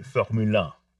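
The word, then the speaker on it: Formule 1: My understanding is that the French pronounce it Formule One. – Wow. – Formula and one are one word, Formule One.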